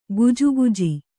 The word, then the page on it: ಗೊಜಗೊಜ: gojagoja